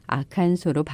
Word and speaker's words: Arkansas: 아칸소 발음을 어떻게 할지 이미 오래전부터 아칸소 주민들 사이에서도 논쟁이 있었다고 해요. 결국 1881년에 주의회가 아칸소로 발음하기로 하면서 일단락 된 거라고 합니다.